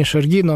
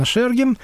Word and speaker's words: Шергин: Вот специалисты говорят, что надо ставить ударение на первый слог. Не Шергин он, а именно Шергин.